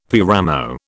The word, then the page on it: paramo: British English